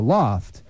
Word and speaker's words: L0pht: A group of Boston-based sophisticated computer hackers called The Loft, pronounced loft because it's not spelled that way. It's spelled L0PHT.